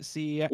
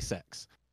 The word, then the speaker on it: CeX: Everyone pronounces it CEX, ooh. – But on their adverts they say sex. – No, yes. – No one calls it sex.